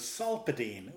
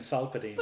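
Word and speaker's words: Solpadeine: Having said that, sorry, just on the point of view and Denise, you both thought it was sulfadine was the name of the popular pain relief tablet. – You pronounce PH as an F. – There isn't the PH in it, though. There's just a P.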